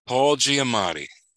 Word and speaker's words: Giamatti: Paul Giamatti.